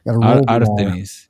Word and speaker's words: Artemis: Artemis. Yes. Okay. No, it's a tough R, not a roll R.